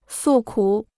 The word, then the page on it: 诉苦: sù kǔ